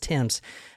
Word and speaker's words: Thames: In England, T-H-A-M-E-S. – Okay. – So a lot of people would think it was Thames, but it's actually spelled Timms, or pronounced Timms.